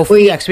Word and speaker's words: FEEX: There's a great website called Feeks, F-E-E-X. – Oh, Feeks.